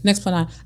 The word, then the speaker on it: Nexplanon: I thought about Nexplanon, did I say it wrong? Okay, yeah. Nexplanon.